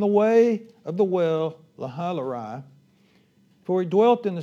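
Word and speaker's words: Lahai-roi: It's a well called Lahailarai. Lahailarai actually is the true pronunciation.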